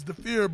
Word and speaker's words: Dhafir: You know, and my name is Dafir, but a lot of people couldn't pronounce it.